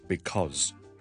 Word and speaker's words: because: 這個字我們日常非常常見，也經常都會講的一個字，就是因為 because B-E-C-A-U-S-E。我們懶的時候或者講話很快很口語的時候，通常就這樣會讀成 because 而這個都還是一個 Z 音來的，所以 because 咁呢個 Z 咧，你見到個 voicing 咧，個 Z 嗰個 z 嘅 voicing 咧係特別明顯嘅。